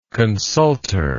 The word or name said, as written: consultor